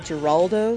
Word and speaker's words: Giraldo: And just a quick correction, that's Neil Giraldo with an L, and I will take the L for mispronouncing his name.